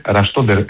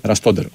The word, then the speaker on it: Ραστόντερ: Ραστόντερ είναι το όνομά του, εκεί τονίζεται. – Ραστόντερ, ωραία.